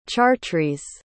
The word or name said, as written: chartres